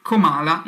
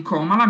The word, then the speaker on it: Comala: Detto questo, iniziamo il nostro discorso sulle Aule Studio, perché partiamo da una di quelle più forse famose, che si chiama Comala, non Comala, mi raccomando l'accento è sulla seconda A, che si trova in corso Francesco Ferrucci 65.